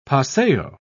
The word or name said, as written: paseo